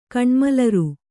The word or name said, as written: ಕಣ್ಮಲರ್